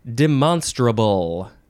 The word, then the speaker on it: demonstrable: That is how you pronounce that word. Demonstrable. Adjective from the 15th century. 1. Capable of being demonstrated. Now we could say demonstrable, but I don't think that sounds silly. Demonstrable? No, demonstrable. But it does sort of sound like a demon or a monster or a something.